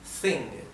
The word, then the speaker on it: think: Acá en think es como, sería como una Z, como una Z española, ¿sí?